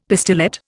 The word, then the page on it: pistillate: Indian